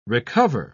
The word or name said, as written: recover